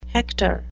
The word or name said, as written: hector